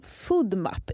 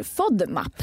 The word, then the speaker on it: FODMAP: Och idag så ska vi prata om FODMAP. Är det så du uttalar? – Faktiskt inte. Det kallas för FODMAP.